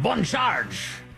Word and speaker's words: Bon Charge: I want to thank the incredible people with Bon Charge. That's the way you say it. A little French pronunciation.